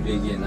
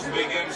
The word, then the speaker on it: begin: Y nota la acentuación begin. Estamos acentuando la segunda sílaba. También no es begin, más un sonido e, que no existe en español. Tengo un entrenamiento que puedes bajar 100% gratis para trabajar ese sonido. Begin.